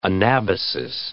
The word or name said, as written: anabasis